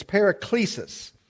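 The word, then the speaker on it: paraklesis (παράκλησις): That's how we would put it in our English vernacular if we were pronouncing it. It's paraklesis.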